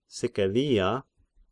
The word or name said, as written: Sicilia